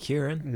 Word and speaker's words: Huron: How do you say that? Huron? – Yeah, Lake Huron.